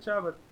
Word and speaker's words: Shabbat: Yeah, good Shabbat.